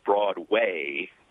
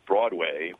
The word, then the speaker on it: Broadway: which you about 80% of the time will pronounce as Broadway, with the emphasis on the second syllable as opposed to Broadway, which is the way I'm accustomed to hearing it, as if you're trying to make sure people don't confuse it with Broad Street or Broad Boulevard or something else.